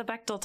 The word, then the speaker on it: Bechdel: Yeah, and then you also do the, is it Bechdel test? Did I say that right? – Yes, the Bechdel test. Yep.